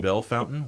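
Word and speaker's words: Bellefontaine: And what's funny is living in Ohio, in a place where we have cities like Bell Fountain, spelled Bellefonte, except it's pronounced Bell Fountain.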